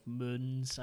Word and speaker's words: Morgans: Yeah, it's like Moans. – Like the guy at work, Moans is his name. It's spelled like Morgans, but it's pronounced Moans.